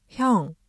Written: 형

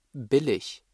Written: billig

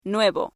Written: nuevo

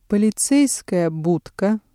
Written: полицейская будка

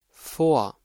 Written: vor

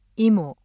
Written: 이모

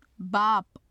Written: bap

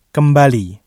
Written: kembali

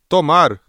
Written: tomar

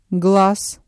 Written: глаз

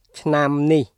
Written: ឆ្នាំនេះ

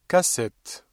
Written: cassette

